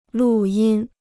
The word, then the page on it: 录音: lù yīn